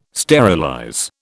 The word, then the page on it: sterilize: British English